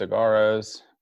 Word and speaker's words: Saguaros: That is the surprise Cigarros. – Saguaros. – I'm mispronouncing that.